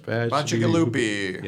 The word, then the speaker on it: Bacigalupi: Bacigalupi.